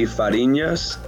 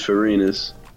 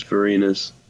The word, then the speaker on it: Farinas: So like the Cuban pronunciation would be Farinas. – Oh. – But like the Americanized pronunciation is Farinas. – Wait, say the Cuban again? – The Cuban one would be Farinas. There's like an ñ on the end. – Farinas. – But I go by like the American pronunciation, which is Farinas.